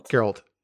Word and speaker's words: Geralt: Geralt.